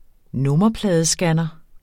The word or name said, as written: nummerpladescanner